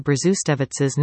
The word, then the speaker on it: Brzustovich: The trick is to basically ignore the first Z, hit that W, with a Germanic V sound, and emphasize the second syllable, giving you Brzustovich.